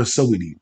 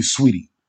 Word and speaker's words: Saweetie: Why am I calling her Saweetie? That's how it's spelled, but it's really Sweetie.